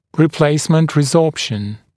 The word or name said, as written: replacement resorption